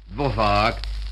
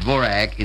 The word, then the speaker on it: Dvořák: Antonín Dvořák, or it's pronounced Dvorak in America, and those of Rudolf Rimmel.